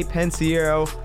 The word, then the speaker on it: Pensiero: It's V-A-T-T. – I like it. Make sure you follow me on Twitter, Thomas J. Pensiero, P-E-N-S-I-E-R-O.